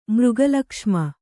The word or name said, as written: ಮೃಗಲಕ್ಷ್ಮ